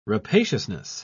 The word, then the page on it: rapaciousness: British English